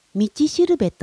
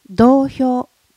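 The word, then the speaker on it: 道標: 同票と書いて道しるべと読みますが、私はそのまま同票と読むのが好きです。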